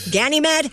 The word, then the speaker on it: Ganymede: G-A-N-Y-M-E-D-E. Ganymede?